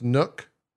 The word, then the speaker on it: NUC: And they're starting to package them in full desktops or NUC, N-U-C. That's what it is. Is it pronounced NUC? It's pronounced NUC. Okay.